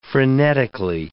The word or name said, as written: frenetically